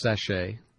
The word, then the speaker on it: sachet: It is pronounced sachet, S-A-C-H-E-T, a small disposable bag.